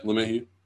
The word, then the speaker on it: LeMahieu: Yeah, LeMahieu.